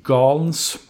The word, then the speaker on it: Gaines: Austin har också värvat Anfallaren eller ytten McKinsey Galns, har jag lärt mig att det ska uttalas, fast det stavas Gaines.